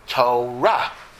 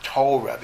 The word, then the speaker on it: Torah: and Torah also is Torah, not Torah, because in Hebrew, most words are accented in the last syllable, not the first syllable.